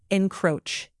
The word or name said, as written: encroach